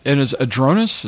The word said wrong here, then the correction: Aroldis: is that how you say it? – Aroldis, yeah.